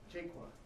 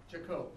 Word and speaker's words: Jaco: Okay, and then I think Fred, looks like Jaqua. – Jaco. – Okay, sorry about that.